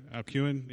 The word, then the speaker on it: Alcuin: He also serves the college as the director of the Aleutian Society. I think I said that wrong. Alcuin? Yeah, there it is.